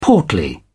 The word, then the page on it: portly: British English